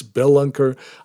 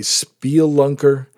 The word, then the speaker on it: spelunker: Although, to be honest with you, I've been using this word actually mispronounced for a number of years. It's spelunker. I always used to say spielunker, but either way, it sounds like it shouldn't be a cave person.